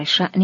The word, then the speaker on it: شأن: در شان او، در شأن او، درباره کار و حال او، شن را گاه در فارسی شان تلفظ می کنند.